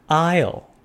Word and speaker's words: aisle: 那你现在站上公车了，看到公车内的景象，就来到了第五个单字 aisle。 A I S L E，aisle 走道是名词，S 不发音。